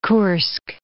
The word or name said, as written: Kursk